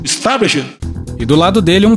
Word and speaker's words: establishment: Eu estou lutando contra um sistema, contra o establishment, contra o establishment, establishment. – Não, você tá falando errado. É, fala, repete comigo. Es, es, tá, tá, blish, blish, ment, ment, establishment.